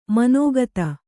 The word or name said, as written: ಮನೋಗತ